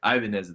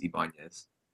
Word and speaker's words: Ibanez: So I say Ibanez at the bar, but the real way to say it is Ibanez, like the Spanish pronunciation.